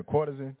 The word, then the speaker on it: courtesan: a Cartesian. Did I even say that right? I should have read that word before I got up there. Somebody said, I'm supposed to be a journalist, right? A courtesan? Thank you, Mama, a courtesan.